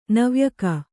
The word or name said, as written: ನವ್ಯಕ